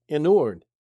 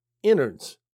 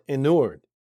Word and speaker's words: inured: To the modern mind, that could sound a bit confusing, especially that word pronounced inured. Not innards, as internal body parts, but inured.